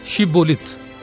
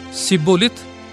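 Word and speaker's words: شبولت (שיבולת): اور اگر وہ کہتا کہ نہیں، تب وہ اسے کہتے کہ شبولت، اور اگر وہ کہتا سبولت، کیونکہ وہ اس کا صحیح تلفظ نہیں کر سکتا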